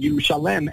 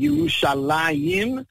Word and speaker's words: Yerushalayim: And of course, we know that Yerushalayim is called Yerushalayim, even though we mentioned before it should be called Yerushalem, and it's written as Yerushalem. Why do we call it Yerushalayim? Because in Hebrew, all the ayims, all the yud mems that are pronounced ayim are pairs. Yadayim, einayim, oznayim, all of them represent pairs.